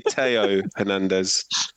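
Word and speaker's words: Théo Hernández: It's actually Theo Hernandez. – Sorry. – It's the French pronunciation of the.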